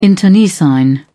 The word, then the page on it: internecine: British English